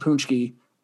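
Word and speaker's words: pączki: The Polish language is fantastic, because that's spelled P-A-C-Z-K-I and is pronounced Punchki, which I can't explain that to anyone.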